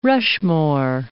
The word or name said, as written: Rushmore, Mount